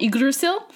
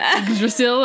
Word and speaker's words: Yggdrasil: And he like makes a point of saying like Yggdrasil, and I'm like, that's how you say it.